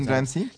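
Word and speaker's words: Lynne Ramsay: Lynn Ramsey, así es como tú lo pronuncias. Lynn Ramsey.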